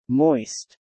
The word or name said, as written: moist